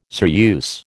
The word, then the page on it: ceruse: American English